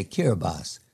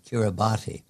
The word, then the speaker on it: Kiribati: For example, you taught me how to say Kiribati and not as it's spelled, Kiribati.